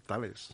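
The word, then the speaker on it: Thales: Y resulta que también tenemos eh, eh, una empresa que se llama Tales, creo que lo pronuncio bien, Tales,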